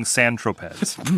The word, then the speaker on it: Saint-Tropez: Saint-Tropez? – Not to be confused with a Pink Floyd song, Saint-Tropez. – Pronounced exactly right.